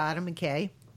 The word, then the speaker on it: Adam McKay: Yeah, Adam McKay.